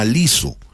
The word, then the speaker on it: Lizzo: Porque ahora en Arequipa ha llegado, ya hace algún tiempito atrás, un nuevo partner de Yango que se llama Liso, L-I-Z-Z-O,